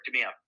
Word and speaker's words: DeMeo: It's my name, Derek DeMeo. That's my handle. – Would you spell it? – D-E-R-E-K. – Yeah. – D-E-R-E-K-D-E-M-E-O.